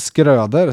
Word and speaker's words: Skröder: Jonas Skröder, som eh, det är ju såklart så hans efternamn uttalas. Var det Färjestads powerplay som var bra eller Leksands boxplay som var dåligt i lördags?